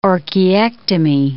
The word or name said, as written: orchiectomy